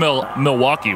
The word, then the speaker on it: Milwaukee: Now, he's from Milwaukee, which, you know, is pronounced Milwaukee, right, which is Algonquin for the good land.